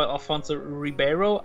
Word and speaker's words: Alfonso Ribeiro: Then there's, of course, Carlton Banks, Will's cousin, played by Alfonso Ribeiro.